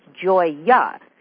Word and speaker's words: Gioia: Your name, I was chatting with you before we went on the air, I said, I've been mispronouncing it. I've been pronouncing it Gioia, and you said, no, it's Gioia. Your last name, which is spelled G-I-O-I-A, is the word for joy.